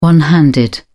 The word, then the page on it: one-handed: British English